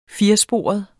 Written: firesporet

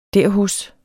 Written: derhos

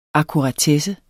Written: AKKURATESSEN

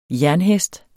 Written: jernhest

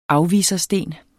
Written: afvisersten

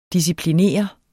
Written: disciplineret

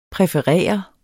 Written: præferere

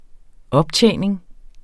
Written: optjening

